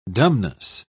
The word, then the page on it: dumbness: British English